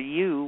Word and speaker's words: Yew: And of course, I find that really interesting, because another layer is this whole thing of grandmother Yu, Y-E-W, is the name of, Y-E-W is the name of a tree, but it also is pronounced the same as Y-O-U.